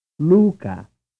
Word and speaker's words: Luca: Now say the Italian name that corresponds to the English Luke. It is spelled L-U-C-A. – Luca. Luca. Luca. – Frame 24. What have we been calling the following vowel sound? – U. U. – The answer is vowel 2. Frame 25. Is Italian vowel 2 stressed in the following name? – Luca. Luca. – The answer is yes.